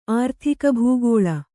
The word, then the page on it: ಆರ್ಥಿಕಭೂಗೋಳ: kannada